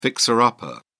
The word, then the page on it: fixer-upper: British English